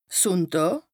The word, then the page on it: Sùnndàà: Bas-Rhin; Strasbourg